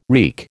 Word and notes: reek: American English

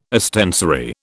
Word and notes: ostensory: British English

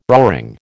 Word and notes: roaring: American English

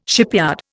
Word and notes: shipyard: Indian

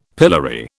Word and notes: pillory: British English

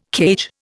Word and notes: cage: Indian

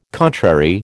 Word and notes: contrary: American English

